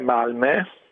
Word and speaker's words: Malmö: La pronuncia è Malmö, con quella la O con i due punti sopra, che a differenza che in tedesco non è da considerare come una dieresi, ma è proprio una vocale a sé stante a questa pronuncia, che è una E.